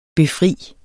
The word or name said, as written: befrier